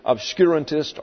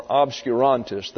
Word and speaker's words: obscurantist: It has two pronunciations, obscurantist or obscurantist. The first one is obscurantist.